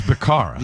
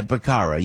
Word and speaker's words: Becerra: Look, Joe Biden couldn't even pronounce his name. – It was Becerra. – Yeah, Becerra. Yeah, that's better.